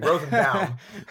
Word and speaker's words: Rosenbaum: I'm Jewish. I could say his name properly. Rosenbaum.